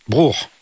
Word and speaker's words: Bruch: Vous avez choisi le Kol Nidrei de Max Bruch. – Bruch. – Voilà. Voilà, Max Bruch. Ah, merci pour le cours de prononciation.